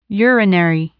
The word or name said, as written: urinary